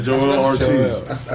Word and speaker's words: Joel Ortiz: Joe Budden and Jrue Ortiz. – Joel Ortiz. – That's how he sounded it out like that. – Thank you. – I don't say it wrong too. It's Joel. – Yeah, Joel Ortiz.